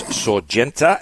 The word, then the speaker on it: Sorgente: He just got off the rowing machine in the gym, and he tells me that it's Sorgente. It's Italian for source, like a headwater of a spring, and it is by Headwater.